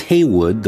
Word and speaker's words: Heyward: Pronounced Haywood, though it appears Heyward on the declaration that he signed.